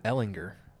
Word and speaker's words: Ellinger: How do you say it? – Ellinger. – Ellinger. – It's a hard G.